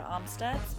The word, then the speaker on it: Amstutz: All of my social media platforms are at Sandra Amstutz.